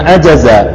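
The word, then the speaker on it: Ajaza (عَجَزَ): Fa in ajaza an satriha, kan gitu ya. Fa in ajaza an satriha. Jadi kalau misalkan ajaza atau ajiza, ikhwah bacanya. Hah? Ajaza ya.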